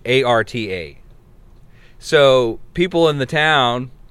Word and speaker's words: Ephrata: Are you familiar with Afreda? Afrada? – Afreda Stairs? – Let's see. – Frida yeah, Kahlo. – Ephrata. It's Ephrata, Pennsylvania is how you say it.